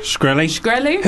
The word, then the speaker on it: Shkreli: Martin Shkreli. – Shkreli.